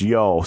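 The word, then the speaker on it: Io: Oh, pronounced yo, sorry.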